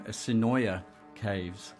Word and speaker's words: Sinoia Caves: And after that, we played a track from the fantastic new soundtrack, Beyond the Black Rainbow, and I'm going to get this right this time, Sinoia Caves. And with me in studio is Jeremy Schmidt, who is Sinoia Caves, and he's been teaching me how to pronounce the name of the of the act better, because I've been playing Sinoia Caves on this show for the last 2 years I've been on NR, and I think I've been getting it wrong every time.